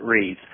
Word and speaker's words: Rives: The one book is called Too Long in the Sun, and both of these books are by Richard Reeves, and he spells his name R-I-V-E-S, but pronounces it Reeves.